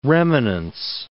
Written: remanence

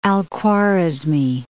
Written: Khwa.rizma, al-

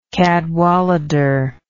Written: Cadwallader

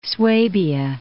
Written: Swabia

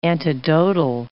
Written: antidotal

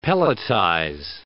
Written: pelletize

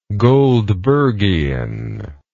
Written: goldbergian

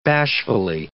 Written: bashfully

